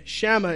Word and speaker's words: Shammah: We see here, the Bible describes, I tried to look up on how to pronounce his name. Shammah is how they said it.